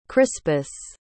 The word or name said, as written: crispus